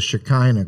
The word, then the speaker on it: Shekinah: Well, you know, it's pronounced, as we were saying a minute ago, us Gentiles call it the Shekinah glory. I found out from Jews that some of them pronounce it the Shekinah.